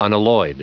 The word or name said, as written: unalloyed